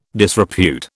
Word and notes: disrepute: British English

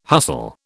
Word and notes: hustle: British English

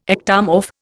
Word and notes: ectomorph: Indian

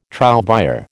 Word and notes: trouvère: American English

